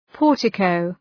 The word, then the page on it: portico: ‘pɔ:rtı,kəʋ